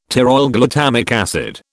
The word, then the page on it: pteroylglutamic acid: British English